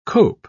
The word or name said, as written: cope